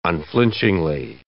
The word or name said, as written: unflinchingly